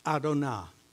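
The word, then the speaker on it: Adonai: That's Adonah. That's how it's pronounced. They would come across YHWH and they would say Adonah.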